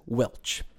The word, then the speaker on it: welsh: This usage is sometimes pronounced as Welch.